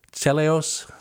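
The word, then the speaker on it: Teleos (τέλειος): And in the Greek, I guess the best way to pronounce it would be teleos. Would that be correct? You had more Greek classes than I did. Teleos? – Teleos? – Yes.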